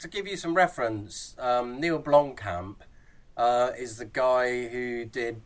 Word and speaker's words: Blomkamp: It's a short, 15-minute short by, I'm going to say this right, Neil Blomkamp. – Blomkamp.